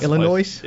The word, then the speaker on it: Illinois: Yeah, that's Illinois. – Yeah, that's the place. The S is silent.